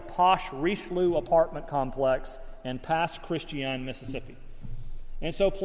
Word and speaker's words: Pass Christian: Pass Christian, Mississippi.